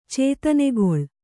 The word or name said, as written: ಚೇತನೆಗೊಳ್